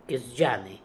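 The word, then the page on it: Kesdjanian: Russian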